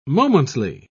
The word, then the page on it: momently: British English